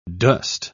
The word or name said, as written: dost